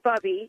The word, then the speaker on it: Bubby: I just wanted to let you know that it's Bubby. It's pronounced Bubby.